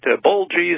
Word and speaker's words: Boehlje: Just think of a kitchen bowl and add a G. I'd prefer it's not spelled that way, but bowl G is the best way to think about it.